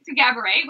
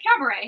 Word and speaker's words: Gabourey: So Gaboury, which her name is spelled G-A-B-O-U-R-E-Y, and she's like, it's pronounced like cabaret.